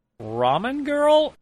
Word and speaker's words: Ramen Girl: Ramen Girl? Did I say that right? – Yeah, you got it.